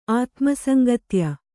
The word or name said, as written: ಆತ್ಮಸಂಗತ್ಯ